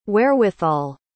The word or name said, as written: wherewithal